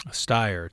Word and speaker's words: Steyer: Steyer, Tom Steyer.